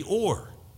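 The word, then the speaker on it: or (אור): And it's not O-R-E, it's O-W-R, but you pronounce it like O-R-E, or.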